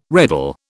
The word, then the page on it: reddle: British English